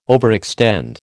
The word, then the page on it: overextend: American English